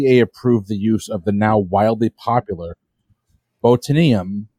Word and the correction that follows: botulinum: neurotoxin. Sorry, botulinum neurotoxin to treat two eye conditions though now it's widely used for other purposes.